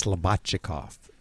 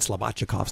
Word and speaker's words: Slobodchikoff: Actually, Judy told me before the show this morning, she said, it's Slobodchikov. And this guy's been on before, and apparently the first time, I didn't pronounce it exactly right.